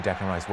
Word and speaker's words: Declan Rice: Uh, we just need to see who Declan Rice wants.